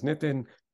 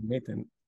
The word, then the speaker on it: Nathan: My name is Nathan. Most people can't pronounce it. It defaults to Nathan.